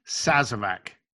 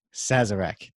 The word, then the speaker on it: Sazerac: Be sure to check that out for the how to make a, how do you pronounce that again? – Sazerac. – Sazerac.